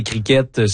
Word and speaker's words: cricket: Et donc oui, peut-être vous avez entendu durant le reportage les mots cricket et cricket selon le site de l'Université de Sherbrooke. On peut prononcer ou pas le T, donc impossible de se confondre avec l'insecte.